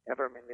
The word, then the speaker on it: Everman: Everman is correct.